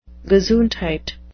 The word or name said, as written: gesundheit